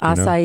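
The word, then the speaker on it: açaí: Acai. You know? That's good. – Acai. – There it is. Acai. – Mm-hmm. – The acai berry from Brazil. Mispronounced quite a bit. How about G-Y-R-O? – Euro?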